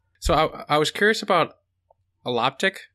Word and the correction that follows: allotopic: if I'm pronouncing that right, expression. – Allotopic, we usually say. – Allotopic, thank you. Allotopic expression.